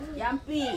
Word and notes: ampi: Ansus

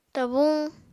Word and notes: tabuñ: Apatani

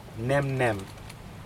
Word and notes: nemnem: Aneityum

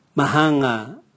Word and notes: mahanga: Raga

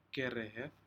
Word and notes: kerehed: Aneityum

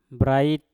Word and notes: brait: mpur

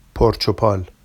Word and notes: porʧopɑl: Vafsi

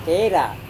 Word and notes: kera: Serewen

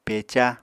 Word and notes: peca: Apatani

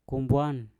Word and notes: kumbwan: Mpur

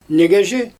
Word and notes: nigezhi: Xyzyl

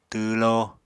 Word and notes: twwló: apatani

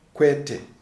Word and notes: Kwete: Shona

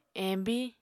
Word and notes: embiñ: Apatani

